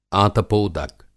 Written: आतपोदक

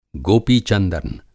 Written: गोपी-चंदन

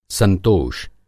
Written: संतोष

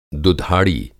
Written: दुधाड़ी

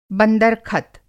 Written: बंदर-खत

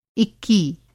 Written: इक्की

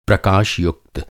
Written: प्रकाशयुक्त

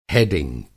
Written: हैडिंग

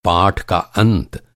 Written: अंत

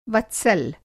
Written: वत्सल

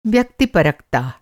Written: व्यक्तिपरकता

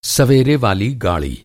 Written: सवेरे

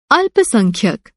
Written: अल्पसंख्यक

